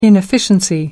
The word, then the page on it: inefficiency: American English